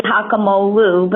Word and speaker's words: Hakimoglu: The G is silent. It's really Hakimoglu, but that's just fine.